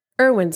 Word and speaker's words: Irwin: Irwin spelled with 2 I's.